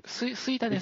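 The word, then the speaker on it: 吹田: スイタですね。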